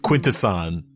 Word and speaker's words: Quintesson: I am not. I am pronouncing it correctly. Quintesson. That's how you say it.